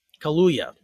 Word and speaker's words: Kaluuya: Kaluuya, that's it.